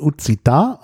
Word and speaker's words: Occitan: Also stand dort das Wort, also richtig ausgesprochen, Occitan, also auf Deutsch würde man vielleicht sagen Occitan, also Occitan geschrieben, O-C-C-I-T-A-N. Und das ist ja der Name einer Sprache, also in der Sprache selber Occitan.